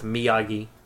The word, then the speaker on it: Miyagi: That's where Daniel finally learns his name's pronounced Miyagi.